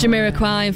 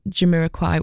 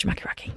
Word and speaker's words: Jamiroquai: They'd only ever just seen it written down and thought that Jamiroquai was actually pronounced Jamaki Raki.